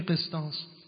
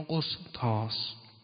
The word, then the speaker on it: قسطاس: کلمه قسطاس یا قسطاس در تلفظ اون در قرآن که هر دو تلفظ رو داریم از واژگان غیر عربی قرآن کریم است.